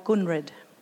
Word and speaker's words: Kunred: So the sites in Gunungu language are called Gunrid, K-U-N-R-E-D, with the K pronounced like a G, as in Kaurna.